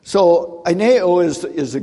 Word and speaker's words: aineo: And that is the word aineo, okay? It'd be an A-I-N-E, long O.